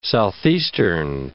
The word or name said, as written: southeastern